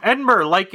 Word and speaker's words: Edinburgh: Edinburgh, like in Scotland.